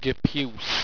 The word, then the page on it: gepuce: Owstwecian